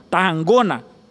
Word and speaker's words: Tarragona: Tarragona, que é essa pronúncia segundo o catalão,